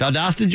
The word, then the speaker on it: Valdosta: In what state is the city of Valdosta in? Is that how you say it? – Valdosta, Georgia.